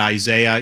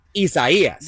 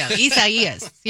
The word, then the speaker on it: Isaias: Say with me. Isaias. – There you go. Isaias.